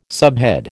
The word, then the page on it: subhead: American English